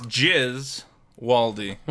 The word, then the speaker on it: Gesualdi: First of all, how do you pronounce your last name, Vito? – It's Jizz. Waldy. – Jizz Waldy. – It's hard to forget the first syllable.